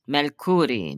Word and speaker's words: Mercuri: Mercuri. Mercuri. So the accent on the first syllable.